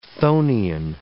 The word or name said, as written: chthonian